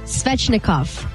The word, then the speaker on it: Svechnikov: Andrei Svechnikov. Did I say that right? – Yes, you did.